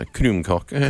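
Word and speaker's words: krumkake: Well, I know there's, do I pronounce it Krumkaka? – Yeah, Krumkaka.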